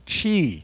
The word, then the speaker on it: qi: They spell it weird, but they call it qi. If you see it spelled, it's qi. I don't know how you get qi out of that, but that's how you pronounce it, qi.